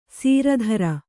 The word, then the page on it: ಸೀರಧರ: sīra dhara